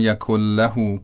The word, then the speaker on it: یکن له: در تلفظ صحیح اینطور باید بگیم، بگیم ولم یکن له کفوا احد، ولم یکن له کفوا احد، یعنی نون رو نخونیم، در عوض لام رو مشدد بخونیم، ولم یکن له کفوا احد.